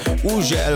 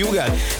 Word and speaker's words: Hugel: Így van, az úriember, akinek minden országon másképp ejtik a nevét. Van, ahol Ugel, van, ahol Hügel, van, ahol Ugel, meg mindenféle kép, de nálunk úgy mondjuk, hogy Hugel, és Salto és a Jamaican Bam Bam érkezik, a csavarjátok fel a hangerőt.